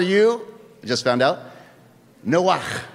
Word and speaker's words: Noach: Now, I'm going to do his pronunciation once. You're going to do it with me a second time, and then we're going to keep it at Noah, right? But it was Noach. Noach.